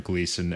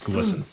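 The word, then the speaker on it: Glisan: And so, yeah, eventually the bus stopped right at the roundabout on Gleason, and it's now Caesar Chavez. We used to be 39th Avenue. – It's also on Gleason. – Excuse me, it's Glisten, according to our pronunciation scholar.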